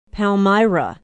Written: Palmyra

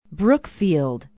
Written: brookfield

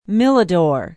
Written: milladore

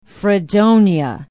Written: Fredonia

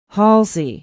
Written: halsey

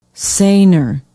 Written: Sayner

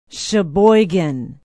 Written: sheboygan

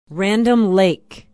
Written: Random Lake

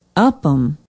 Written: upham

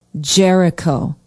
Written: jericho

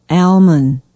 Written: almon

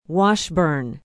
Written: washburn

